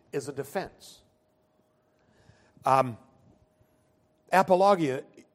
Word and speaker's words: apologia: The word for defense in the Greek is apologia, actually pronounced apologia.